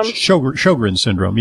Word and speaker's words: Sjögren's: Leaning toward lupus, or I think you pronounce it Sjögren's syndrome. – Sjögren's syndrome, yeah.